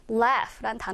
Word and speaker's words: laugh: laugh란 단어는 웃다라는 동사로 조금 이따 볼 건데요. 특이한 것은 발음이 gh가 같이 이렇게 묶여서 이 단어에서는 거의 f 사운드, f 사운드로 된다. 그래서 laugh, laugh로 발음된다라는 거 기억하면 좋겠습니다.